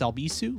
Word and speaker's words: Albisu: And if you'd like to follow me on social media, you can search for me at Alex Albisu. Last name is spelled A-L-B as in boy, I-S as in Sam, U.